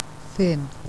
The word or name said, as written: thin